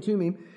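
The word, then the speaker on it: Tummim: Now, we would say Urim and Thummim, but they don't pronounce the THs there. So Urim and Tumim.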